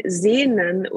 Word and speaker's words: Sehn: So you spell it S-E-H-N, Sehn, and then S-U-C-H-T.